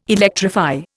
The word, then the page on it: electrify: Indian